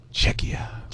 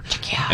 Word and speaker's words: Czechia: Czechia. That's how it's said on the website there. Czechia.